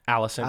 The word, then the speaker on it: Alicent: Is that right? Alicent? – Alicent. – Alicent, yeah.